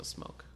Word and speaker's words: Smoak: It's an Evan Smoke? Smock. It's spelt like Felicity Smoke of Arrow fame. – Okay. – That S-M-O-A-K. – That's probably still Smoke.